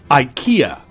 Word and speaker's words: IKEA: I-K-E-A. IKEA. – IKEA. – Now, I don't know, it's a Swedish store,、 yes. – and I don't know how they actually pronounce it, but in North America, we all say IKEA.